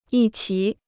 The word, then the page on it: 一齐: yī qí